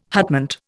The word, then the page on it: hutment: Indian